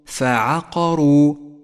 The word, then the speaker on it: فعقروا: الفاء والعين حرفان مرققان، والقاف مفخمة والراء مفخمة والواو مرققة، تقرأ هكذا: فعقروا.